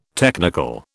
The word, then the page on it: technical: British English